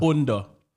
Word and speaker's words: bunda: Bunda. – Yeah. – Bunda. All right. Okay. – I see the way you said that. Yeah, you said it right. That's the way you say it.